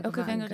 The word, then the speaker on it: Okavango: So we just did the Okavango. Am I saying it right? – Yeah, Okavango Delta.